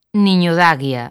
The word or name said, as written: Niñodaguia